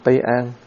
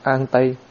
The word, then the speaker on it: An Tây: Cái người đứng bên cạnh thầy họ cười, họ nói cái này không phải là Tây An mà cái này đọc là An Tây.